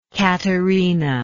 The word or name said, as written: caterina